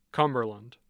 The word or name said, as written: cumberland